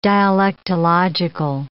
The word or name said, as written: dialectological